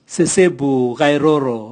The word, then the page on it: sesebu gairoro: Raga